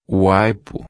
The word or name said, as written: aipo